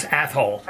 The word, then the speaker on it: Athol: And by the way, for people playing at home, it's A-T-H-O-L. So I know it sounds like something else, but that's how you pronounce Athol.